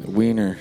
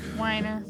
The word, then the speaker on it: Wiener: Is that Wiener? – Wiener. Yes, that is Wiener. – I've never known how to pronounce that last name. – Weiner. – Weiner. – Is it Weiner? – No, it's Wiener.